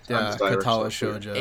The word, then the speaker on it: Katawa Shoujo: That's how I pronounce it. How did you guys pronounce it? Because you probably know better. – It's pretty much the exact same. – Right, yeah, I would say that. Katawa Shoujo.